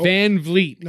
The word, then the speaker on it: VanVleet: They say VanVleet. – It's VV. Think about it like that. Oh, VanVleet. No, you want to say Fleet, you know?